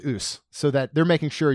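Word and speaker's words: A'ass: But it's pronounced ouse, but it is it is A apostrophe A S S.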